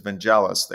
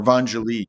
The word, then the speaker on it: Vangelis: I guess a lot of people make that mispronunciation, but actually, he's living in France, and the way you pronounce it is Vangelis there, or Vangelis. So everybody pronounces it differently. I simply pronounce it the way it's introduced to me in Greek.